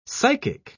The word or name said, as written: psychic